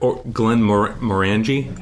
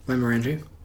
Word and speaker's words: Glenmorangie: So, like, Glenmorangie? How do you say that? Do you know how to say that? – You did it right. Lime orangey.